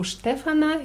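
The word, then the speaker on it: Стефана: U Stefana, hier ist die Betonung wieder auf E.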